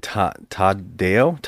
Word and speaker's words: Tadao: This also stars Tadeo, how do you say it? Tadao Takashima.